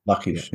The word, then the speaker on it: Lachish: They've just been lost, such as the 11 to 1200 corpses that you talked about in Lachish. Did I say that right? – Lachish, yeah.